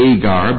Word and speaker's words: Hagar: We have set forth Hagar, no H's in the Greek, so it's pronounced Agar, but in the Old Testament it would be Hagar.